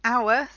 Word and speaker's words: hour: So the word hour sounds like it begins with an O, and that's why it's an hour.